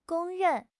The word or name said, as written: 公认